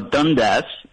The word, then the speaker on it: Dundas: No, Dundas is how you say it.